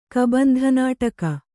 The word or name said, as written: ಕಬಂಧನಾಟಕ